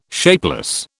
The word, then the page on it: shapeless: British English